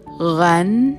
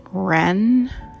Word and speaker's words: Ren: So the beginning word R E N is pronounced Ren, and you should never pronounce it Ren like a English R, but hold your tongue in the center of your mouth to make an R sound, and it comes out uh, uh, Ren.